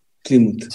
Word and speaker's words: Plymouth: Y ahí podemos ver que ellos llegaron a mm, Plymouth, ¿lo dije bien? – Plymouth. Sí.